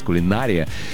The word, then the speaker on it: кулинария: Но сейчас кулинария в некоторых орфоэпических словарях помещается как устаревшая. Так написано. А вот в авторитетном словаре Майи Зарвы, который рекомендован сотрудникам радио, телевидения, предпочтение дается ударению на «и», то бишь, как вы сказали, кулинария.